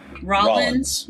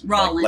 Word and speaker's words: Rollins: Rollins, Rollins. Rollins. Like, like I'm sorry, like Collins, like Phil Collins.